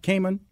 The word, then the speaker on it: Kamen: Inventor Dean, I believe I want to say his name right, Kamen. Is that right, Kamen? – Yep.